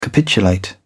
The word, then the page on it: capitulate: British English